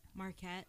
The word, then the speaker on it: Marquette: He is still alive, and he's in Marquette? Is that how you say it? – Marquette State Prison.